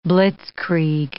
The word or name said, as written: blitzkrieg